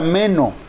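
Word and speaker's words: meno (μένω): Y la palabra permanecer en el idioma griego, griego se pronuncia meno, que generalmente significa quedarse en un lugar, en un estado o en una relación o expectación dado.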